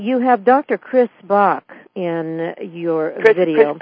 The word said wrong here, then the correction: Chris Bache: Chris Bache.